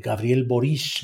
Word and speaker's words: Gabriel Boric: Gabriel Boric,